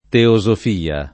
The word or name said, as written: teosofia